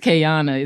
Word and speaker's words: Kayana: So for some reason, people want the O to have a long O sound, and it doesn't. It's more relaxed. It's K A. It's Kayana.